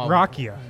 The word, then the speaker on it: rakia: What is the Serbian drink? I never know how to say it. Rakia? – Rakia.